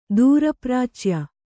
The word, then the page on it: ದೂರಪ್ರಾಚ್ಯ: dūra prācya; kannada